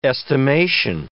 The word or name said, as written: estimation